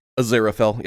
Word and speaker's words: Aziraphale: Aziraphale? Is that how they pronounce the name? – Aziraphale, yep.